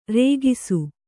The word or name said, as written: ರೇಗಿಸು